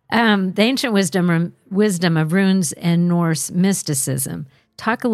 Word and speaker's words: runes: Now, talk about a little about the ancient wisdom of runes. And am I saying that correctly? It's R-U-N-E-S. – Yep. – Runes. That's what I thought.